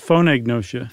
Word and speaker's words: phonagnosia: do you pronounce that G there? – I think it's phonagnosia. – Phonagnosia? Okay, that makes sense.